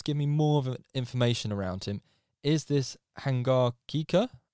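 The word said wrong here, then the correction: Hangargekar: Give me the proper pronunciation, because obviously I'm butchering it. The under 19 Seema. – It's Hangarikar.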